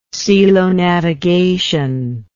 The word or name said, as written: celonavigation